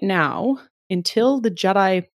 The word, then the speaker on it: GEDI: So it's GEDI with a G, but pronounced a la Star Wars, as you pointed out.